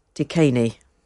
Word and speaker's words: DKNY: My daughter, who's a social worker, once worked with a young girl, a long time ago it was, and her name was DKNY, pronounced Decaney.